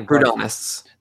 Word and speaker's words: Proudhonists: It was opposed by the Proudhonists. I have pronounced that right? Proudhonists. – Yeah, Proudhonists.